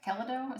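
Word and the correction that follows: Keldeo: is that how you say it? – Caldeo. – Caldeo.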